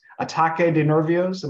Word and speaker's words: ataque de nervios: I'm not pronouncing this. Ataque de nervios. Am I anywhere close, guys? Yeah, okay, we get some thumbs up.